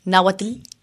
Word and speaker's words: náhuatl: En náhuatl, ahora lo he dicho mal, náhuatl, voy aprendiendo, ave preciosa, pájaro rojo, viene a significar poeta.